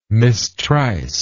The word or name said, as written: mistryst